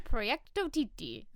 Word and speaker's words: Proyecto Titi: Proyecto Titi. – Proyecto. Oh, that makes sense. Okay. Proyecto Titi. Nope. Proyecto Titi. – Yes. – I'm adding an extra syllable. Proyecto Titi.